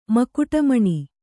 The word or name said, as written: ಮಕುಟಮಣಿ